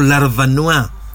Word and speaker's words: Larvanois: Eduardo Larvanua, apellido francés, se escribe Larvanois.